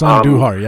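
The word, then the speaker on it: Andújar: I'm going to mispronounce his last name because it's usually you know Andujar, but I think he goes by Andujar. – Yeah, it's Andujar. Yep.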